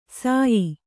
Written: ಸಯಿ

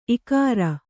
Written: ಇಗುರು

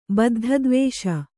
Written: ಬದ್ಧದ್ವೇಷ

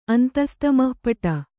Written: ಅಂತಸ್ತಮಃಪಟ

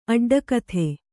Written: ಅಡ್ಡಕಥೆ

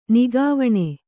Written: ನಗೆವೆಣ್ಣು

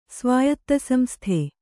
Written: ಸ್ವಾಯತ್ತಸಂಸ್ಥೆ

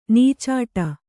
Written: ನಿಚ್ಚಟ

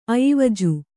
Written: ಅಯಿವಜು

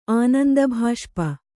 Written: ಆನಂದಭಾಷ್ಪ

